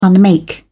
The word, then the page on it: unmake: British English